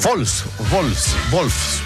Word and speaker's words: Wolfsburg: No, ese Wolfsburg, porque la W se pronuncia como V corta, ¿no? O V, ¿no? Se dice V.